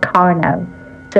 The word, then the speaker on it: Carnot: And they used the name, or he used the name, Lazare Carnot, or Carnot, but I don't think the T is pronounced in French. So Lazare Carnot.